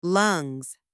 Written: lungs

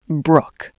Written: brook

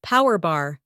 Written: power bar

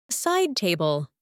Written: side table

